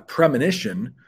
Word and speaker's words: premonition: A premonition, pronounced just a little bit differently there, but it's a forewarning, a feeling that something bad is about to happen. Okay? It's a premonition.